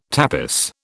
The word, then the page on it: tapis: British English